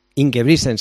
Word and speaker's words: Ingebrigtsen: Y para ello nos vamos a fijar en la figura de Jakob, a ver si lo digo bien, se dice Ingebrigtsen. – Ingebrigtsen, sí.